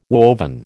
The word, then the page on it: woven: American English